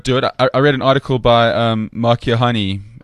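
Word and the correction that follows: Keohane: if I'm pronouncing his surname wrong, I'm sure I am. – Kiohane. – Yeah, one of them. Mark. Kiohane.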